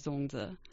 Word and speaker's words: zongzi: which is zongzi.